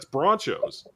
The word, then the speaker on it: Bronchos: And I said, that's not Broncos, that's Bronchos.